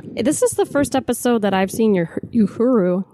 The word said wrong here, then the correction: Uhura: That's hard to pronounce. – Uhura.